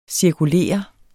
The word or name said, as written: cirkulere